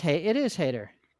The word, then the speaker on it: Hader: I don't know if he pronounces it Hader or Hatter. – Oh, yeah, it is Hader.